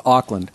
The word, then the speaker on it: Auckland: it's pronounced Auckland. – Auckland. – When are you guys going to learn how to pronounce the name of your own city? – New Zealand. – Oakland is over there. We're near Oakland. That's where the Athletics and the Raiders play.